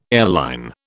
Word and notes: airline: British English

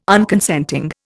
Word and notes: Unconsenting: Indian

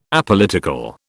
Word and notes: Apolitical: British English

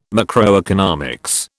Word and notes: macroeconomics: British English

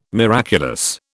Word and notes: miraculous: British English

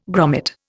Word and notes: grommet: Indian

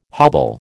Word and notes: hobble: American English

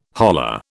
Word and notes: holla: British English

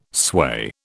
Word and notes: sway: British English

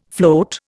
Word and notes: float: Indian

